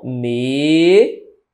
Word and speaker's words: medíocre: Medíocre é assim, ó. Medíocre. Correto? Correto. Veja que a sílaba tônica é esse di, bem aqui, ó.